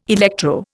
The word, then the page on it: electro: Indian